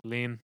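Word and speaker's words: Lene: I think it's Lean. I think it's Lean. – Lean? – Just because when I looked up pronunciation, everything was like Lean.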